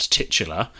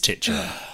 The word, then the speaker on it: titular: Last week, when Ben pulled me up on my pronunciation of titular, I very adamantly said, it's not titular. And again, having consulted my phonetic and BBC Oxford English pronunciation guides, both of them, I'm wrong. It is titular.